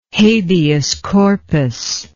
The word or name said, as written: habeas corpus